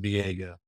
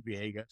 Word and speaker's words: Viega: Anyway, you look at it in the English context, it looks like it would be pronounced Viega.